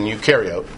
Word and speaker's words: eukaryote: The prefix EU, pronounced U as in eukaryote, eukaryotic, that means true.